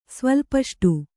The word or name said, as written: ಸ್ವಲ್ಪಷ್ಟು